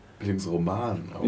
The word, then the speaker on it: Bildungsroman: Bildungsroman.